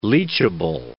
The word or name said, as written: leachable